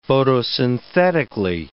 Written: photosynthetically

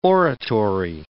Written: oratory

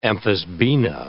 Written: amphisbaena

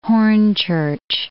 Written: Hornchurch

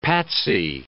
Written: patsy

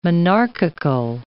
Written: monarchical

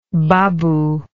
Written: babuism

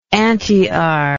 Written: antiar

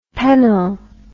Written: pennill

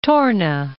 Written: Torne

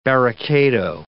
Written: barricado

